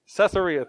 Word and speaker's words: Caesarea: Caesarea, thank you.